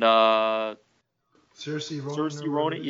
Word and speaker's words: Saoirse Ronan: Saoirse Ronan? And Saoirse Ronan.